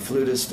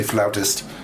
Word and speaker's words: flutist: So we say flautist. – We don't. We say flutist. I think the Brits say flautist, but in America and in France, we say flutiste or flutist.